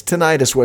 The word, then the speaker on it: tinnitus: There is a raging war over the pronunciation of this affliction. Tinnitus or tinnitus? – It's tinnitus, as far as I'm concerned.